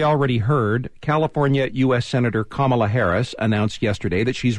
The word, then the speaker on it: Kamala: Harris's first name is pronounced Kamala. It's derived from ancient Sanskrit and is a nod to her Indian heritage.